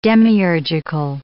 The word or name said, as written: demiurgical